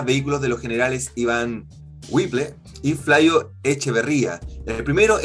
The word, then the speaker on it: Whipple: Somos chilenos, Whipple.